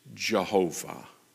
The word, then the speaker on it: Jehovah: We pronounce it either Yahweh, which is the more modern pronunciation of that, but the older pronunciation coming out of the German that found its way in the King James is Jehovah.